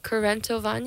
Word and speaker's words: Kurentovanje: I think it's pronounced Korentovanje. Yeah. – That's probably better than I can do. – Well, let me spell it. K-U-R-E-N-T-O-V-A-N-J-E. And it's a Slovenian celebration of spring.